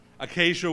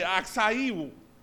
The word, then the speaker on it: Acacia: And I did not want to get up here and say acai wood.